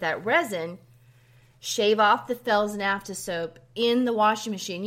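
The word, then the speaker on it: Fels-Naptha: It's spelled Fels, F-E-L-S, then hyphen Naphtha, N-A-P-T-H-A. That's Felsnafta soap.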